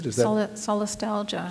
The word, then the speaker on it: solastalgia: like a made-up new word that called solastalgia. Is that how you pronounce it? – Solastalgia. – Solastalgia.